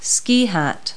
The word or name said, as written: ski hat